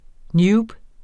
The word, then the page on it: newb: ˈnjuːb; ˈnuːb